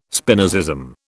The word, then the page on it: Spinozism: British English